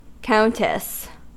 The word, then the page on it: countess: American English